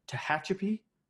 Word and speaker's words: Tehachapi: It's a double New England IPA from local craft beer out of Tehachapi, California, which I definitely had to look up how to pronounce, just so you guys know. hopefully I did an okay job. – Yeah, you did a good job.